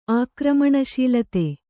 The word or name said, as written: ಆಕ್ರಮಣಶೀಲತೆ